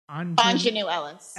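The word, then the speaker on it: Aunjanue Ellis: Judi Dench for Belfast, Kirsten Dunst for Power of the Dog, and how do you pronounce her name from King Richard? I don't. – Anjanou Ellis.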